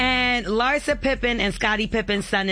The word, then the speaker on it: Larsa: Larsa. That's how you say it.